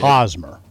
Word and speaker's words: Hosmer: Hosmer.